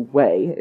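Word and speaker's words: Hue: In the town, in the city of Wei, they are headed to Wei. It's H-U-E. That's how it's pronounced.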